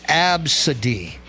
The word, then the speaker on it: Abcde: The name A-B-C-D-E. It's pronounced Absidy.